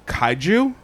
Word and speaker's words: kaiju: And she posted an excerpt from Wikipedia for I'm gonna mispronounce it probably, because it is a Japanese word, kaiju, K-A-I-J-U. – Yeah. – I think kaiju is how I've always...